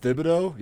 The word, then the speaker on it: Thibodeau: And then just isn't it pronounced Thibodeau? Yeah, if you say it wrong, that's yeah, sometimes if you're wrong.